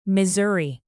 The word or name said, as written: missouri